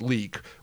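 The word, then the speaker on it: Leyak: The Penanggalin, the Karasu, and my favorite, the Liak, which is actually pronounced leek, which is, if anyone's ever seen Mystics in Bali, Chris, I know you and I have watched it.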